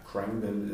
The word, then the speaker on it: Khruangbin: Und äh, ja, Krangbin ist wahrscheinlich die englische Aussprache davon. – Mhm. Und es ist K-H-R-U-A-N-G B-I-N. Also hört es euch ein paar Mal an, dann könnt ihr es äh, mal googeln.